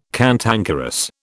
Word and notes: cantankerous: British English

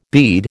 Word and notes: bead: American English

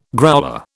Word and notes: growler: British English

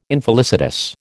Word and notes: infelicitous: American English

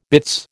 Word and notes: bitts: American English

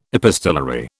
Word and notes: epistolary: British English

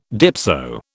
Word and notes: dipso: British English

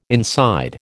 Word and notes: inside: American English

